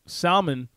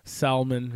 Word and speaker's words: salmon: Salmon, that's how you pronounce it correctly. In Spanish, it's salmon, right? So I always want to pronounce the L, salmon, right?